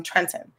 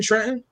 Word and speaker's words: Trenton: You grew up in Trenton? Yes. I never heard anybody from Trenton pronounce the second T.